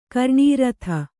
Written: ಕರ್ಣೀರಥ

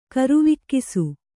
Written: ಕರುವಿಕ್ಕಿಸು

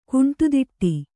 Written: ಕುಂಟುದಿಟ್ಟಿ

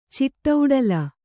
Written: ಚಿಟ್ಟೌಡಲ

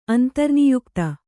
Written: ಅಂತರ್ನಿಯುಕ್ತ

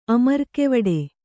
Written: ಅಮರ್ಕೆವಡೆ

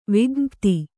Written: ವಿಜ್ಞಪ್ತಿ

